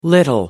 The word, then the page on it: little: American English